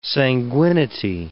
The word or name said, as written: sanguinity